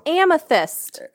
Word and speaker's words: amethyst: Amstith. It's a stone. It's a stone. It's a birthstone. – Oh, amethyst.